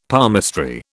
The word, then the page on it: palmistry: British English